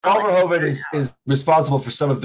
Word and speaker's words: Verhoeven: Paul Verhoeven. You have to say it. – Verhoeven. – The original RoboCop was directed by Paul Verhoeven. It was his eighth. Am I saying it right? – Yeah, that's perfect.